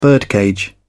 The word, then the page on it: birdcage: British English